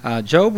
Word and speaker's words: Job: It looks like the word Job, but it's a long O. And just to give a little history here, Job was a very wealthy man.